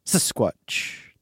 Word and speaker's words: Sasquatch: Sasquatch. It's a proper way to say it.